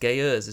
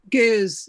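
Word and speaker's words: Ge'ez: Beady-eared listeners will hear that the language is pronounced Geuz, is that right? – Geuz, yeah. – Geuz, okay. – There's an apostrophe in between. Yeah, I said Geuz.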